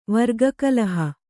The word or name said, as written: ವರ್ಗಕಲಹ